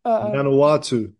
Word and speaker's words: Vanuatu: Pardon me if I mispronounce it, but Vanuatu. – Vanuatu, Vanuatu. – Vanuatu. All right, there we go. – Close. That was close.